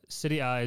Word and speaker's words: Serie A: Serie A is Juve Napoli.